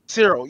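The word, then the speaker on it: Syril: There wasn't really one bad character except for, was it Civ? How you say his name? – Cyril.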